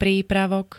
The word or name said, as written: prípravok